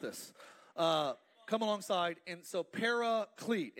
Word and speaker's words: paraclete: That is the Greek word paraclete. That's how we pronounce it. It actually is pronounced differently in the Greek, but paraclete is how we would say it in America.